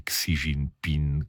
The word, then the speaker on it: Xi Jinping: Era Fabiano Rizzi che mi faceva notare questa cosa della pronuncia di Xi Jinping con la G che non dovrebbe starci, quindi lo ringrazio perché mi ha svelato una cosa che non sapevo.